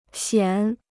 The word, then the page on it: 显: xiǎn